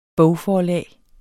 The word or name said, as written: bogforlag